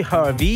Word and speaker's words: HRVY: Eh sì, ho capito, però se lo pronunci Hrvi.